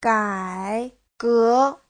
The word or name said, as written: gai3ge2